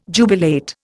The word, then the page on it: jubilate: Indian